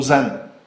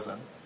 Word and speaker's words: Xen: A teď se objevil virtualizační nástroj, který se píše Xen. Všichni anglosasové to vyslovují Zen. Pokud teda nejsou, no, takže my máme teď dva produkty, jeden se píše Zen a vyslovuje se Zen, druhý se píše Xen a taky se vyslovuje Zen.